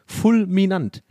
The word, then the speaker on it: fulminant: Also, so also für alle, die es nicht gehört haben, ich Real Talk, ich wusste nicht, wie man Fulminant, also ich dachte wirklich, da ist noch eine Silbe, Fulminant, aber es ist ja tatsächlich Fulminant.